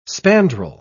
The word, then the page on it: spandrel: British English